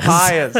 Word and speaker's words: papayas: Papuas? Papayas. Papayas. – Papayas. That's how you say it.